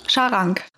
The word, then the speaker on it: Scharang: Wie spricht man das richtig aus? Charon oder Charang? Charang.